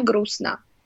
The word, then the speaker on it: грустно: So, and then the next thing they hear is грустно, right? – Yeah.